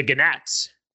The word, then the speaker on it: gnat: So when we get to the third plague, then, the gnats, you have to pronounce the G, that's gnat.